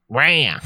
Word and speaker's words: Raya: Is it Raya or Raya? – Raya.